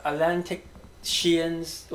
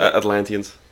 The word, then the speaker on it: Atlanteans: What Atlantic Sheans, right? Or how to pronounce it? – Atlanteans.